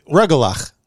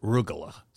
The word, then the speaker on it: rugelach: How do you pronounce that? – Well, rugelach. – We pronounce it rugelach. So you see, we already have a battle.